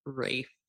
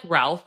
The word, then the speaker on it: Ralph: Rafe, like something with an R. – I never know that man's name. I'm huge. – Because it looks like Ralph, but that's not how they pronounce it.